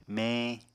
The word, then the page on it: meñ: Apatani